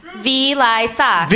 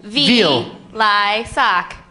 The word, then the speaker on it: Vilaysack: Vilaysack. – Vilaysack. – Vil. – Vilaysack.